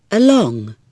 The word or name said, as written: along